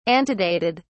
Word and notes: antedated: British English

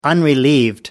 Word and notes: unrelieved: American English

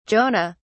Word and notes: jonah: British English

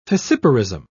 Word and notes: fissiparism: British English

